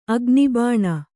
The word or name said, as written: ಅಗ್ನಿಬಾಣ